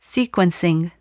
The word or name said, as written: sequencing